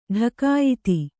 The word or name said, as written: ಢಕಾಯಿತಿ